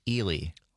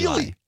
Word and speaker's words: Ely: I will say, though, Phil in Minneapolis just called in and says it is pronounced Ely. – Oh, really? – Eli.